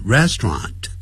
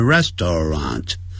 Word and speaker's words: restaurant: restaurant 念的时候就念成 restaurant restaurant，不要念成 restaurant restaurant restaurant restaurant。